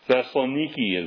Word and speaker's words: Thessaloniki: Thessaloniki is the way they pronounce it today.